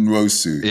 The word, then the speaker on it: Nwosu: Nwosu.